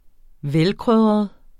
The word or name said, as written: velkrydret